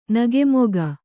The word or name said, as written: ನಗುಮೊಗ